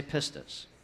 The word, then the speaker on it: pistis: Everybody say pistis.